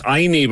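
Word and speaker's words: Ainy: Inie, by the way, is spelt A-I-N-Y. A-I-N-Y. Okay? Inie. I know, you wouldn't have a clue until I told you.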